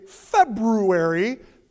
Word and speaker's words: February: Today, Sunday, February, pronounce that first R, February 10th, 2019.